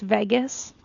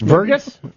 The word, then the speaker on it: vagus: So what we're trying to do is stimulate the branch of the vagus nerve. – Oh. – Here's another thing. – It's pronounced vagus. – Open vagus. – Vergus? – Vagus. – Vergus. – Fly to Vegas.